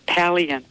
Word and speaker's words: Palaian: It's Palian.